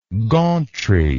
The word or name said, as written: gauntry